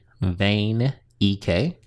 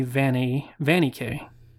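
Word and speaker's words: Vanek: Tony Vane EK. You said Vane. – It's like veiny. V-A-N-E. – Like a weather vane.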